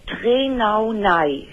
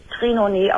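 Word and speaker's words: Trénaunay: Und dann Trenaunei, hinten mit Y. Mhm. Dann Syndrom, das wird Trenaunei ausgesprochen.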